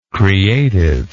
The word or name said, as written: creativeness